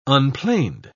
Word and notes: unplaned: British English

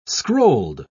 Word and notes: scrolled: British English

